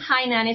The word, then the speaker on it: Hainan: How do you spell that, Hainan? – Hainan. It's H-A-I-N-A-N.